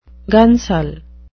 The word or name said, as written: gunsel